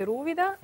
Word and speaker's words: Rúvida: Ah, então eu não pronunciei bem o nome do restaurante, pois não? – Ah, Rúvida. – Rúvida. Exato, Rúvida. É, em italiano nós escrevemos os acentos, é, é, é só saber.